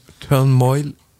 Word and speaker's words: Turmoil: Alors, après le Play 3, c'est Turmoil. Ça se prononce comme ça? – Turmoil. – Ouais, il manque un N. Hein. C'est Turmoil.